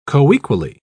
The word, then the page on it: coequally: British English